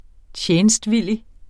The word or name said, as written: TJENSTVILLIGT